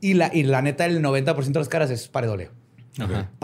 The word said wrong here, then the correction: pareidolia: Pareidolia. Se me, se me, olvida pronunciar esa I. La I. Pareidolia.